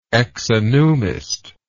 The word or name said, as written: exonumist